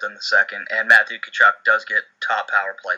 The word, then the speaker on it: Tkachuk: If I'm going to go in that range, I'll save $200 and go to Matthew Tkachuk, if I said that right. – Yeah. Matthew Tkachuk. – Tkachuk, silent letters, man.